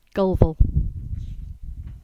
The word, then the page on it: Gulval: English